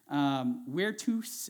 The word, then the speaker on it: virtus: Virtus is how you say that in Latin. And from virtus, you get the word vir, which looks like V-I-R.